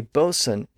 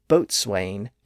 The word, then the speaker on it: boatswain: But again, that's the second syllable, and it's an unstressed syllable, and in that phonetic environment, the W sound was lost near the beginning of that syllable, giving us modern-day bosun and coxswain.